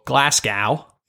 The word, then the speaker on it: Glasgow: They also did not re-sign, but sort of re-signed an offensive lineman, Graham Glasgow, as we would say in the UK, or Glasgow, because we're in America, so that's how people pronounce it for some god-awful reason.